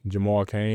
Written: jamal CAIN

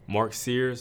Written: mark SEARS

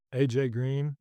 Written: AJ Green

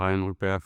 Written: Rayan Rupert

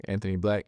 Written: anthony BLACK

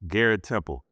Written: Garrett Temple

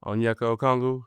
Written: Onyeka Okongwu

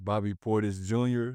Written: bobby PORTIS JR

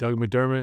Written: Doug McDermott

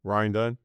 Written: Ryan Dunn

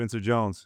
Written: Spencer Jones

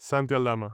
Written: santi ALDAMA

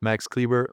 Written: Maxi Kleber

